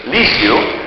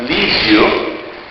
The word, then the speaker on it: Lisieux: a true story about St. Thérèse of Lisieux, as some of my French parishioners reminded me last night, my pronunciation is not correct, Lisieux.